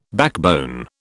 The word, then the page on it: backbone: British English